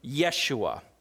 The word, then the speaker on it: Yeshua: See, the name Jesus is actually a Latin translation of a Greek name, which is translated from a Hebrew name, Yeshua.